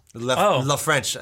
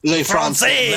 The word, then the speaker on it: français: Le French, I believe it's how it's pronounced. – Yes, Le French. – Le Français. – Le Français. Oh, God, yes, that's actually how you say it. – Ooh. – Not Le French.